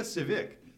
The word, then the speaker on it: Tsvyk: Victoria Nasirova gave her beautician, Olga Sivik.